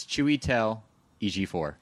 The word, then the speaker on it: Chiwetel Ejiofor: It's Chiwetel Ejiofor. – Chiwetel. – It's easy to say EG4.